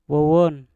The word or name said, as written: wowon